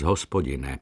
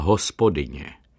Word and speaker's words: Hospodine: Tak v jedné modlitbě, kterou říkával po mši svaté, přicházela slova vyslyš nás, Hospodine. On však vyslovoval Hospodině. Upozornil jsem ho na to, poněvadž jsem viděl, jak komicky to působí. Zarazil se a namítl, vždyť je to správně, Herre uns o Gott, ne milosti. Ve vaší výslovnosti to znamená Herre uns o Hausfrau. Zo, vyhrkl překvapen, a jak je tedy Herre uns o Gott? Vyslyš nás, Hospodine, opakoval jsem to asi třikrát za sebou a potom pronesl roztrpčeně, tak vidíte.